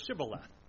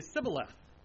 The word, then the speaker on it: shibboleth: The problem is, for the Ephraimites, they cannot pronounce shibboleth correctly. They have difficulty pronouncing it, or at least their dialect is developed in such a way. Instead of saying shibboleth, they say sibboleth.